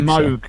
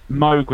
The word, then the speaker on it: Moog: Moog, so. Moog was supposed to rhyme with Vogue.